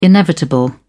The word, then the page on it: inevitable: British English